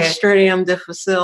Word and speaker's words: Clostridium difficile: Clostridium difficile, also known as C.